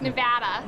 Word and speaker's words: Nevada: Am I saying it right? – So close. It's Nevada.